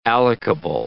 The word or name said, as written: allocable